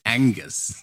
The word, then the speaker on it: Angus: Es ist falsch ausgesprochen, Andreas. Mhm. Es ist Angus.